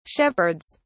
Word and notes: shepherds: British English

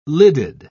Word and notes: lidded: British English

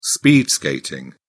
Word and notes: speed skating: British English

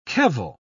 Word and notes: kevel: British English

